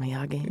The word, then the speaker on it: Miyagi: in your Hollywood biopic, you've said that Anthony would be Mr. Miyagi. Is that how you pronounce it? – Mr. Miyagi.